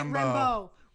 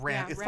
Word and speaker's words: Rambeau: Well, that's right, Rambeau. Rambeau is her last name. – It's more, French, like Rambeau. – Yeah, it's spelled B E A U, but yeah. Still, it's Rambeau.